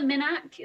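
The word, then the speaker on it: Minack: Is it the Minnack? Is it the Minnack Theatre? – Yeah.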